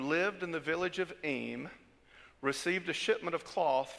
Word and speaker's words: Eyam: He said, there's a story about a village called Eyme. It's spelled E-Y-A-M.